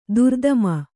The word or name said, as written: ದುರಾತ್ಮೆ